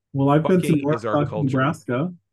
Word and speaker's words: Norfolk: Which is spelled like Norfolk. – Uh-huh. But they say Norfolk. – In the town's history, they will tell you that that's because the postmaster misspelled it. And Nebraskans are too nice. They didn't want to hurt his feelings. So they were like, we can spell it that way. It's fine. they pronounce it Norfolk.